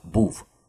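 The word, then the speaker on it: bov: По-шведски «БОВ» — преступник, хотя произносится не «БОВ», а «БУВ».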